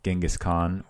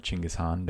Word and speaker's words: Genghis Khan: Genghis Khan or Chinggis Khan, depending on which pronunciation you prefer.